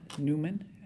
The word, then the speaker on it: Newman: This is Linda Newman, and my last name is spelled N-E-W-M-A-N.